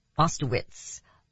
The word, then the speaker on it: Oszustowicz: John and Lynn Ostovitz. Ostovitz. O-S-Z-U-S-T-O-W-I-C-Z.